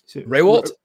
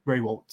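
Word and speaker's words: Rehwoldt: Raywalt? – Raywalt, yes.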